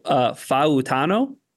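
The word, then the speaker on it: Fautanu: Let me make sure I'm getting the pronunciation right, because I know it's important on the show and in all things. So Faautano. – Yeah.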